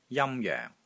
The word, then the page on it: JAM1 JOENG4: Cantonese